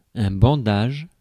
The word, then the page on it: bandage: /bɑ̃.daʒ/